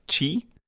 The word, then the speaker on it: Qi: but I believe it's basically pronounced Chi. Um, the Q spelled Q I.